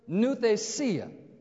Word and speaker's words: nouthesia: It's the word nouthesia. Phonetically, it'd be spelled N-O-U-T-H-E-S-I-A. Nouthesia.